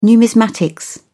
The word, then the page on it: numismatics: British English